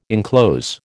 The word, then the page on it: inclose: American English